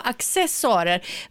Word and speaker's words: accessoarer: Jag har nämligen fått lära mig för länge sedan av Sture Allén att accessoarer ska uttalas med hårt K, accessoarer.